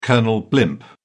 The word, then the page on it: colonel blimp: British English